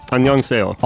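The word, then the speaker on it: Annyeonghaseyo (안녕하세요): And then there's a whole bunch of characters that I don't understand that are pronounced Annyeonghaseyo from Seoul. – That's Korean for hello. Annyeonghaseyo.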